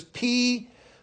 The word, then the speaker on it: Pygmalion: First of all, I had to look it up and see how you pronounce it because it's Pygmalion.